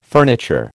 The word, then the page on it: furniture: American English